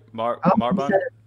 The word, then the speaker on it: Marbán: Marbon? – Perfect.